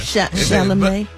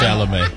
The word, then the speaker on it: Chalamet: Chalamet. – Oh, that's how you say it. – Yeah, Chalamet.